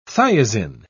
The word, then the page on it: thiazine: British English